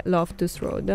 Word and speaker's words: Loftus Road: E qua si giocherà invece al Matrade Loftus Road.